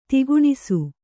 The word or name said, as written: ದಕ್ಕಣಿಸು